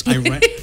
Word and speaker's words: Iran: Not Iran. It's Iran. Yeah, you know how the Americans pronounce Iran, right? – If you go Iran.